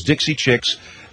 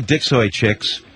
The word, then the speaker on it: Dixie Chicks: And by the way, their name is Dixie Chicks, not the Dixoy Chicks.